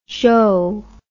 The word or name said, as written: zho